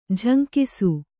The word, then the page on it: ಜುಂಗಿಸು: kannada; juŋgisu